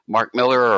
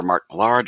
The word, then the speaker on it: Mark Millar: Mark Miller or Mark Millar, depending on how you want to pronounce it.